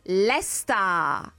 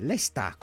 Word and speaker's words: Leicester: Allora, si pronuncia, la mostro alla radio, Lesta. – Ah, facile, Lesta, con l'H finale.